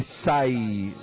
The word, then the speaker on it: Sai: Esta es la premisa de Sai, se escribe S-A-I, Sai,